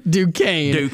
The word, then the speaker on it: Duquesne: I don't know what Duquesne, or how do you even pronounce that team? I'm not taking them. I'm out. – Duquesne. There you go.